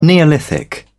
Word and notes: neolithic: British English

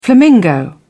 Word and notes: flamingo: British English

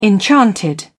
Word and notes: enchanted: British English

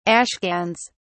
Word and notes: ashcans: British English

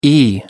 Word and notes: e: American English

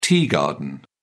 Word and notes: tea garden: British English

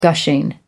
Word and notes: gushing: American English

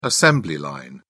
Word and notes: assembly line: British English

